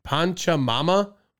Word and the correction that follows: Pachamama: If that's how you pronounce it. – It's Pachamama.